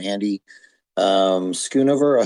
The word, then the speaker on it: Schoonover: but I want to welcome Andy Schoonover.